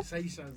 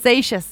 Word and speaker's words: Seixas: ¿se dice? ¿Cómo se dice? Paul Seixas. – Es francés. – Sí, ¿verdad? – Seixas. – ¿No? Seixas.